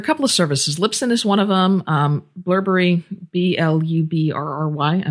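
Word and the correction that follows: Blubrry: I may not be pronouncing that right, is another. But I think it's Blueberry. – Blueberry? – Yeah. – Blueberry.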